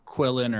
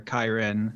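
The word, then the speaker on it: qilin: I think some people have theorized this is what a Kalin or something like that. It's like a Chinese dragon. – Yeah, it has different pronunciations. It'd be Quillen or Kyren.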